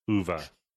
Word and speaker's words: Uwe: Uwe.